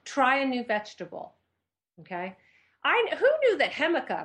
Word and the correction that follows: jicama: am I saying that right? Hemica. How do you say that? – Jicama? – Jicama. Jicama, it's quite yummy in a salad.